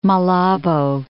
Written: Malabo

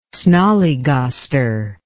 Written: snollygoster

